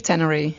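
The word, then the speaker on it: Ténéré: And you did something even more spectacular called, I think it's called, the Tree of Tenere. Is that how you say it? – Tree of Tenere. – Tenere. – Yeah.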